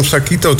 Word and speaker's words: Zakito: Zeg, gisteren nog de vraag, is het Zakito of Zakito? Want de klemtoon, het accent staat op de O. Dus je zou zeggen Zakito, ja, dus, maar dat klinkt zo onnatuurlijk.